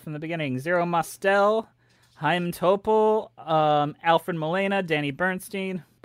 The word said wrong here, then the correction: Burstein: Bernstein? I don't know how to pronounce it. – Gwen Stefani. – Danny Burstein. – Burstein.